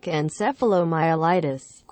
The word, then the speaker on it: encephalomyelitis: Myalgic encephalomyelitis, chronic fatigue syndrome.